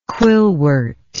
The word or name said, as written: quillwort